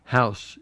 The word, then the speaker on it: Howse: How do you say his last name? House? – House. – H-O-W-S-E. – H-O-W-S-E. Mm-hmm.